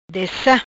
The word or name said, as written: Dessa